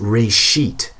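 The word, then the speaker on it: reshit (רֵאשִׁית): But the Hebrew phrase being used here is actually a single word pronounced reshit.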